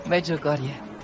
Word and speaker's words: Medjugorje: Medjugorje.